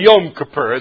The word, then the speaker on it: Yom Kippur: Actually, Yom Kippur is how it's pronounced, but the Day of Atonement.